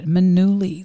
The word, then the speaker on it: Manuli: Is there any other way to pronounce it? I guess Manuli. – No, that would be double L-Y.